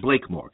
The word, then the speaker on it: Blakemore: It was directed by Michael Blackmore, I believe, who is a very well-known Australian. Michael Blakemore. That's how well-known he is here. I get his name wrong.